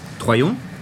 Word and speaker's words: Troyon: Estoy en la calle Troyon, ¿Sí? así se pronuncia.